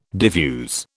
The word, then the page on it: diffuse: British English